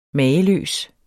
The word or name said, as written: mager